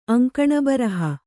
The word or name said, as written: ಅಂಕಣಬರಹ